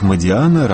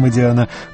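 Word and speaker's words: Модиано: Патрик Модиана, роман «Кафе утраченной молодости». Во Франции, разумеется, ставят ударение на последний слог, но Модиана – итальянская фамилия.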